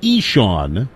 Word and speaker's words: Ixan: He goes by Eshawn, which is spelled I-X-A-N, pronounced Eshawn.